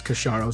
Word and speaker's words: Casciaro: But yeah, essentially, so for anyone that doesn't know, my last name is pronounced Casciaro.